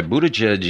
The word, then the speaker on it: Buttigieg: And some suggestion as to why Buttigieg is... oh, doggone it, I said his name right.